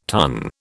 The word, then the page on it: tonne: British English